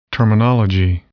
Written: terminology

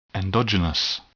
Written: endogenous

